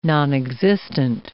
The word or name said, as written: nonexistent